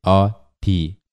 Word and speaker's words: 어디: So, the standard romanization system that the Korean government uses is not T-H-I, but the actual pronunciation of this word, T, is T-H-I. We're using D-I here, but please make sure that you're not pronouncing the word 어디 as E-O-D-I, but please say 어디, D-T-H-I.